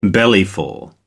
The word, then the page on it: bellyful: American English